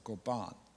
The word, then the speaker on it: goban: Stresses on the second syllable, goban.